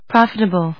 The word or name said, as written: profitable